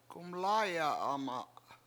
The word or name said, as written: gumLAAya7ama7